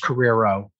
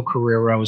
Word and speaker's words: Carriero: How do I properly say your name? – Rocco is my first name. My last name is Carriero. So Rocco Carriero is my name.